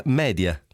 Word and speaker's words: media: media è una parola latina, il plurale di medium, preciso visto che non lo sanno, e quindi si pronuncia media, viva Dio, e non media, in una vana e indisponente scimmiottatura di una pronuncia texana, con la quale, grazie al cielo, non abbiamo niente da spartire.